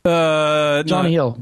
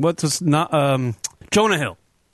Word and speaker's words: Jonah Hill: Oh, you're talking about Joanne Hill. Jonah Hill. – No. You got it all wrong. Jonah Hill.